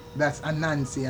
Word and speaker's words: Annancy: How do you spell that? – A-N-N-A-N-C-Y. Brother, that's Anansi, and short they say Bra Anansi, meaning Brother Anansi.